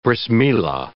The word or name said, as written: Brith Milah